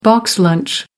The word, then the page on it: box lunch: American English